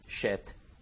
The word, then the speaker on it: Shet: I don't know if I'm pronouncing it right, Seth, but in Hebrew it's Shet.